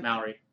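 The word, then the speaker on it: Māori: Maori people, I'm sorry if I'm pronouncing Maori. – Maori. Thank you.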